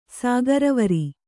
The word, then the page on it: ಸಾಗರವರಿ: Kannada